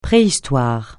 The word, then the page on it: préhistoire: francais